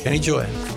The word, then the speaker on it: Kenny Gioia: Is it Kenny Joya? Is that the right way to say your name? – That is correct. Kenny Joya.